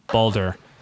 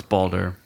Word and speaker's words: Baldur: I still think one of the standout scenes, obviously I haven't gotten that far, was the first battle with Baldur Balder? Balder. – Yes. – Can't pronounce this one right now. – Balder. – It's Balder.